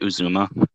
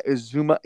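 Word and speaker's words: Uzomah: You mentioned something earlier in our DFS podcast about the tight end Usman Zah or whatever, however you say his name. – CJ Uzuma. – Yeah, Uzuma.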